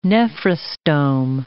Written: nephrostome

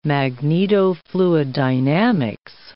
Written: magnetofluiddynamics

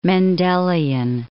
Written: Mendelian

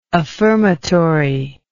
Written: affirmatory

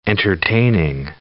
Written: entertaining

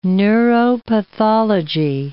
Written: neuropathology

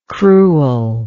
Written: cruelness

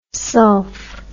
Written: sulph

